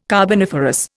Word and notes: carboniferous: Indian

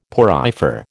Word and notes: porifer: American English

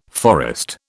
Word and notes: forest: British English

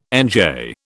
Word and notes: NJ: British English

